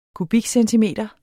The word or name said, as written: kubikcentimeter